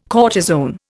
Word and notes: cortisone: Indian

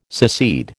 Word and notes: secede: American English

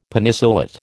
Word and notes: Penicillate: American English

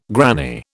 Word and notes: granny: British English

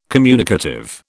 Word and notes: communicative: British English

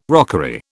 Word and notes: rockery: British English

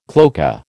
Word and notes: cloaca: American English

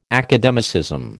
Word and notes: academicism: American English

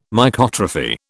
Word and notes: Mycotrophy: British English

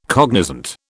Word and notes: cognizant: British English

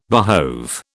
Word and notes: behove: British English

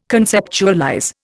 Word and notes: conceptualize: Indian

